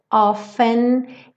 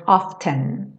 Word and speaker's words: often: Eu coloquei o T aqui pintadinho de cinza, como uma letra muda, porque no inglês americano o T do often não é pronunciado. Já no inglês britânico você pode pronunciar e dizer often, often.